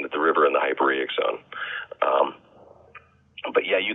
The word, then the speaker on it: hyporheic: Yeah, yeah, the hyporheic zone.